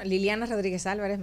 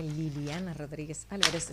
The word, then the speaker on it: Liliana Rodríguez Álvarez: Bueno, Liliana Rodríguez Álvarez, mi nombre se escribe con doble L, Liliana Rodríguez Álvarez.